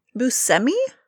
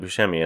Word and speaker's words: Buscemi: Everybody says it Buscemi, and I do too often. But did you hear him say that recently? – I'm pretty sure it's Steve Buscemi.